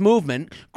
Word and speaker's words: MVMT: With fair prices, unexpected colors, and clean original designs, Movement, MVMT, pronounced Movement, grew into one of the fastest growing watch brands, shipping to over 160 countries across the globe.